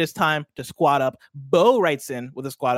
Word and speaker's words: Beau: That's spelled B-E-A-U James. Bo James.